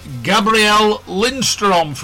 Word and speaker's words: Gabriel Lindström: I'd like to welcome to the Backseat Driver Radio Show, Gabriel Lindström from Sweden.